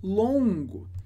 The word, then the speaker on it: long: Muito longo. E aí, importante. Long significa longo. Que é diferente de longue, que é longa. Perceba a diferença de pronúncia. Primeiro, long. Long. Sem G, termina no som on, long, que é longo, e long, long, é feminino, longa.